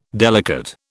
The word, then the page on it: delicate: British English